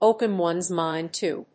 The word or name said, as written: open one's mind to